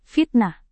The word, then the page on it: fitnah: indonesian